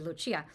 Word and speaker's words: Lucia: they're naming their children Giorgio and Lucia or Lucia, as it would be pronounced there.